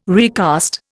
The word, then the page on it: recast: Indian